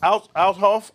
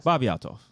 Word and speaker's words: Althoff: Yo, Shorty, that the white joint that's interviewing niggas, that Bobby Adolf. – Althoff. – I can't stand. – I don't know how you say it. – Whatever her name is. – Bobby Althoff. – Yeah, Bobby Althoff.